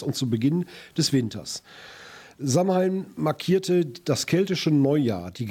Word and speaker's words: Samhain: Ausgesprochen Samhain. Samhain, da gab es auch mal eine Heavy-Metal-Band, die so sich nannte.